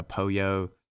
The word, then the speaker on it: pollo: Uh, the uh, Poyo Poyo feed, um, which is P O L L O.